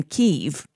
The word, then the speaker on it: Kyiv: ambassador to Ukraine in Kyiv,